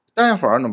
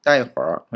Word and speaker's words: 待会儿: で注意はですね、この待つという漢字、も普通は第 4 声なんですね。第ですけど、待会儿の場合は第 1 声で発音する場合も多いです。待会儿、待会儿、まあ、どっちでもいいんですね。よくあるのは第 1 声で発音するそうです。